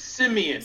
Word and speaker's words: Siemian: That's Trevor Siemian, or Siemian. I'm not sure if I'm saying that right. – I believe it's Siemian.